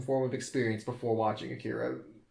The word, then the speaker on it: Akira: I mean, you're putting really too much emphasis on the I, because it's Akira.